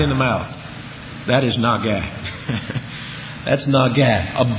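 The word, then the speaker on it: Naga (נגע): Naga, N-A-G-A, Naga.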